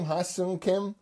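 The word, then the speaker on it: Ha-Seong Kim: And my man, Ha-Sung Kim, how do you say his name? Ha-Sung Kim? – Yeah.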